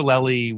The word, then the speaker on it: Lely: how do you say his last name? – Lely. – Yeah.